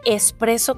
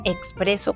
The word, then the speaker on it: espresso: Mm, lo primero que me gustaría platicarles es que la forma correcta de pronunciarlo en español es expreso con S y no expreso con X aunque en algunos lugares, incluso en cafeterías, es común que lo encuentren tanto escrito como pronunciado con X, la forma correcta de decirlo es espresso.